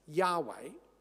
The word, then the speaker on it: Yahweh: And it's also related to the word which most English translations render capital L-O-R-D, Lord. This is God's personal name. The Hebrew is four consonants, Y-H-W-H. And most scholars think this is pronounced Yahweh.